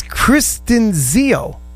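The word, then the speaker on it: Christinzio: when I say that I discovered BC Camp Light, I do want to point out that Brian James Cristiano, I can never pronounce it, it's Kristen Zio.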